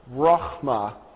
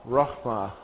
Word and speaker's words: Rachma: The word here is Rachma, Rachma, okay? Now, it's got 3, you're going to see like the aleph here that's not included in the word down here. The first syllable sounds like this, Ra.